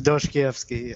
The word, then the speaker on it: Dostoevsky: By Dostoevsky. You're our resident fake accent person. How would you say it? – It's Dostoevsky, yes.